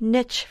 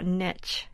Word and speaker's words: niche: And let me talk about niche, N-I-C-H-E. It was a word in English generations ago, up until sometime in the 60s, I think. There was only one pronunciation for that word in English dictionaries, niche.